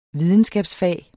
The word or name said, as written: videnskabsfag